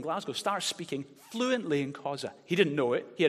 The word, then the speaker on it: Khoisan: How do you say Khoisan? Khoisan. Okay, it's got little clicks in it.